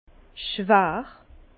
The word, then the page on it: schwär: Bas-Rhin; Herrlisheim